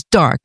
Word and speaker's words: d'Arc: Her family name was Arc, D was Dark, D-A-R-C.